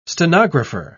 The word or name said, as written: stenographer